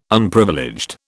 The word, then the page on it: Unprivileged: British English